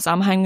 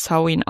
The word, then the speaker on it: Samhain: Ich habe immer Samhain gesagt, aber tatsächlich wird es Samhain ausgesprochen.